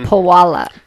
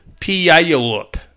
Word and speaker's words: Puyallup: Pualap, Puyallup. – Puyallup. I believe is, is how it's correctly pronounced.